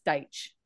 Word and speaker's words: Dyche: And I think with the way that, okay, also too, did you guys see that video of how to actually pronounce Sean Dyche's name? It's Dyche. – Yeah, Deitch, not Deitch, isn't it? Deitch. – Dyche. Yeah, it's Dyche.